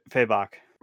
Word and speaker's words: Fabok: I'm just kind of a sucker for like big muscles and you know the David Finch, the Jim Lees, the I don't know how to pronounce his last name, Fabic. Oh, uh, F- Fabok. Fabok, yeah, yeah, just kind of like very good muscular character.